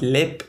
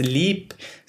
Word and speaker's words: Leap: the comments were like, it's not lip, it's leap. So I'm gonna go with leap because it is spelled castle, L-E-A-P, so like leap castle, and even Google seconds that, so I'm gonna go with that.